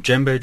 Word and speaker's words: djembe: Well, the drums that we use at, at the drum circle um, are generally um, djembe drums. It's spelled D-J-E-M-B-E. E.